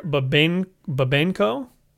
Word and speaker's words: Babenko: We've got Kiss of the Spider-Woman, directed by Hector Babenko. – Good enough. – Totally good pronunciation.